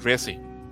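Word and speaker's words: Reze: Riz cuando Riz o bueno, comenta acá nuestro amigo Yuyus en el chat que sí se debería pronunciar Reese porque está escrito katakana. Entonces, pues yo le voy a decir Riz, pero ustedes díganle como quieran, mientras nos entendamos todo bien, ¿verdad?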